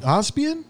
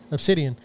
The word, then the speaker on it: Obsidian: Obsidian.